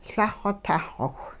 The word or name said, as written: Lah-xw tah xowh